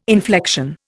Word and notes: inflection: Indian

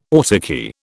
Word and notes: autarky: British English

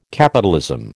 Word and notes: capitalism: American English

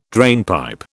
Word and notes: drainpipe: British English

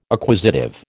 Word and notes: acquisitive: American English

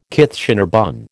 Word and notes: Kitchener bun: American English

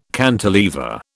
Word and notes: cantilever: British English

